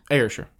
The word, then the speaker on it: Ayrshire: Ayrshire.